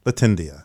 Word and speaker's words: Latindia: Latindia. Am I saying it right? – Latindia. – Mm-hmm.